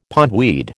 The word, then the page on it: pondweed: American English